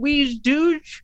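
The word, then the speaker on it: wej Duj: I'm presuming we pronounce it Weege Dooge, because it's technically in Klingon, but we get emails from Paramount with the episode.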